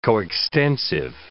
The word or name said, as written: coextensive